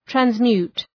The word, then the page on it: transmute: trænz’mju:t, træns’mju:t